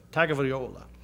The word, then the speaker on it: Tagovailoa: Help me pronounce his last. Tagovailoa.